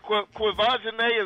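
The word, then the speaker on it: Quvenzhané: Please tell me y'all saw a cute little 9-year-old Quivisione. Is that how I say her name? – Quivisione. Yeah, you got it.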